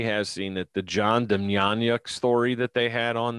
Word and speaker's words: Demjanjuk: Demjanjuk. – Demjanjuk.